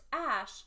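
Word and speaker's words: X Æ A-12: He said, it's just X, then the AE is pronounced Ash, and then A12 is his contribution, which was in honor of the plane Archangel 12, the precursor to the SR-71.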